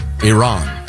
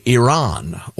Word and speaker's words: Iran: This is from Alan on the pronunciation of Iran. – Okay. – The I can be ire, as in irresponsible. Oh, ear. But Ron, R-A-N is Ran, not Ron, not Ren, not Rin.